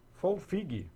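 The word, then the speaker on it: Folfigue: Folfig. – Folfig, o GUE é mudo, 200 Valeu.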